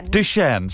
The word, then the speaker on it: Duchenne: So it's pronounced Duchenne. – So it's sort of a French thing going on, is it? – It is French.